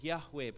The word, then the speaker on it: Yahweh: We pronounce it as Yahweh, but the four Hebrew letters are YHWH.